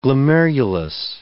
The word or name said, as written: glomerulus